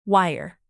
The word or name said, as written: wire